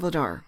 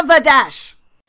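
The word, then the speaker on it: Vladař: Yes, because on a video segment that he did for NHL Bruins on Twitter, he was calling himself Dan Vladar. And I want him to put the dash back. Dan Vladash! – It was Vladash once I got used to the ridiculousness of Vladar being pronounced Vladash.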